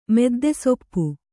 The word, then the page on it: ಮುದ್ದೆಸೊಪ್ಪು: mudde soppu